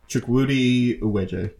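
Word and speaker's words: Chukwudi Iwuji: I apologize in advance for the mispronunciation. Chukwudi Uwe. – Let me look it up. Chukwudi Uwejie. Yep.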